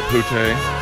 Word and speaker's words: Boutet: Could you please pronounce the T? That would be really nice. – Mr. Boutet.